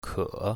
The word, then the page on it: ke3: mandarin